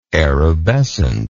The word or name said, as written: erubescent